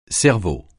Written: cerveau

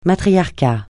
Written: matriarcat

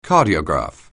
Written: cardiograph

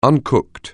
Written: uncooked

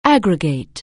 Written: aggregate

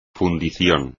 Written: fundición

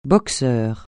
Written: boxeur

